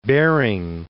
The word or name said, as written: Bering